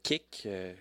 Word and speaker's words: Qik: C'est avec Kik, ça s'écrit Q-I-K.